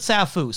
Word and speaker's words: Sappho: It says in the subtitle Sappho, but he pronounces it Safu, so I'm just going to roll with that.